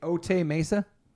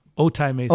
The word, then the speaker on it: Otay Mesa: How do I say it? Ote Mesa? – Otay Mesa. – Otay Mesa. Ote. Yeah. Ote.